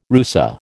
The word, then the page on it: rusa: American English